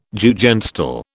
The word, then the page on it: Jugendstil: British English